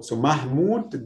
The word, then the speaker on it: Mahmoud: Because now it's a long vowel. Because if I don't add this vowel, it will be Muhammad again. – Yes. – So Mahmoud, then I must add O, long O.